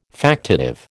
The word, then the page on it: factitive: American English